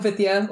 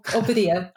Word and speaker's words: Vidya: It's Vidya or Vidya or Vidya. I answer to all three.